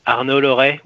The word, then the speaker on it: Arnaud Larre: Yes, you pronounce it almost correctly. So my name is Arnaud Larre with a French accent.